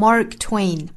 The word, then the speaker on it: Mark Twain: Mark Twain, Twain, Mark Twain，美音呢读成 Mark Twain，后面是一样的，前面美音读成 Mark, Mark Twain，那就是马克吐温。